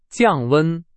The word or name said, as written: 降温